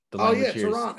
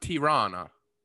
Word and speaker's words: Tirana: Tirana. You're saying it right. – It's actually pronounced Tirana.